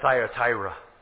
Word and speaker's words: Thyatira: T-H-Y-A, Thya, right? Am I spelling it right? Let me make sure I look. Yeah, T-H-Y-A-T-I-R-A, Thyatira. That is the church of the Dark Ages.